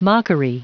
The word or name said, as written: mockery